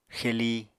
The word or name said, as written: xeli